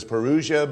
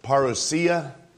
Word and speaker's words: parousia: Some pronounce it as parousia, but it's actually pronounced parousia.